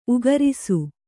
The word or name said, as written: ಊಗುರಿಸು